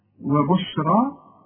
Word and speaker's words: وبشرى: وهدى وبشرى، وبشرى عند الياء بتتحول الى الف عند النطق، وبشرى تكتب بالياء، وبشرى للمؤمنين.